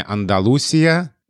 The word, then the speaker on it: Андалусия: Да, потому что слова времен собственных русского языка Флоренции Леонида Нагенко э, а, дает ударение «Андалусия», и название города Малага.